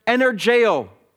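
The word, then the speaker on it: energeo (ἐνεργέω): The, the word work there in the original, in the Greek, it's, it's, it's pronounced energeo, which of course we get our English word energy.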